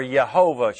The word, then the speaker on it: Yehovah: Or Yehovah Shema, if you want to pronounce it in the Hebrew. The J sounds like an H.